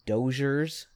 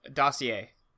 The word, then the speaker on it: dossier: Doss, is that how you say it? – D-O-S-S-I-E-R? – I-E-R, yeah? – Yeah, dossier. – Oh, that's the word. – It's French.